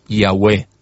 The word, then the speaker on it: Yahweh: Su pronunciación, según los mejores estudiosos de la lingüística y de la filología antigua, probablemente era Yahweh.